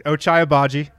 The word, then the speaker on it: Ochai Agbaji: There's Ochai Agbaji. – That's correct. Ochai Agbaji.